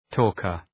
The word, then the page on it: talker: ‘tɔ:kər